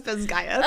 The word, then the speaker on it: Basgiath: Is that how it's pronounced in the audiobook? – It is. It's pronounced Bazgaeth.